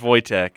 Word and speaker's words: Wojtek: It is pronounced Wojtek.